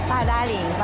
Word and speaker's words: Badaling: So I pronounce it Badaling. – Yeah, Badaling. – Badaling.